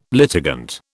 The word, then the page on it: litigant: British English